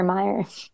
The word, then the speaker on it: Meyer: I like, am I pronouncing that right? Like Oscar Meyer. I don't know. My brain is jumbled. – No, you're right.